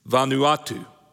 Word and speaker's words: Vanuatu: I kind of put the phonetic pronunciation out there to help me, but that really didn't help, all right? Vanuatu, that's how you say it, all right.